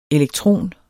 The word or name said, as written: elektron